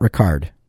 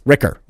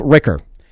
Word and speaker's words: Ricker: Oh, it's Mike Ricker, not Ricard. Not everyone is French.